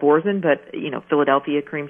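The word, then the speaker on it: Boursin: So then you take this garlic and herb cream cheese, which is called Borzen, and if you can't find it, it's spelled Borsin.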